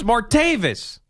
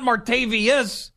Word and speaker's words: Martavis: It's Martavis. It's an odd name, but it's not Martavius.